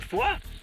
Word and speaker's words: Foix: Hey, Franzmann, wie spricht man Foix? – Da spricht man vor, so wie in Vorgra.